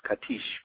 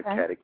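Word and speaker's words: Katić: And, I mean, she is really pronounced Katish, but nobody pronounces it that way, Stana Katic.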